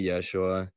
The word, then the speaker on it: Yeshua: we have that sh sound that's lovely so we can we can say yeshua the greek didn't have that so so jesus was was about as good as they could they could do for Yeshua.